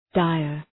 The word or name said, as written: dire